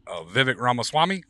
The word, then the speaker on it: Vivek Ramaswamy: Vivek Ramaswamy? – Yeah.